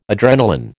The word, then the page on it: adrenalin: American English